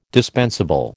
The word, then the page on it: dispensable: American English